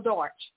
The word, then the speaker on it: Darch: Let me ask four more questions about Carol Darch. And her name, by the way, if I mispronounced it earlier, is spelled D-A-R-C-H, Carol, C-A-R-O-L, last name Darch.